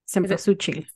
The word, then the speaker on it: Cempasúchil: You had it right, by the way. Cempasúchil. – Okay. – I mean, if it's pronounced like it's spelled.